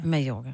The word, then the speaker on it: Mallorca: Siger I Mallorca eller Mallorca? Mallorca. Oh men hvorfor? Det staves jo Mallorca. Men det udtales Mallorca.